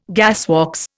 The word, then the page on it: gasworks: Indian